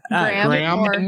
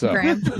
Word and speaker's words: Graeme: No, G-R-A-E-M-E. How do you say that? – Graham. – Nobody's perfect. Graham.